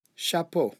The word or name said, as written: chapeau